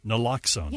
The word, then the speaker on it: naloxone: There was quite a conversation, and I still see the billboards, and I always mispronounce it, Naloxone. – Naloxone.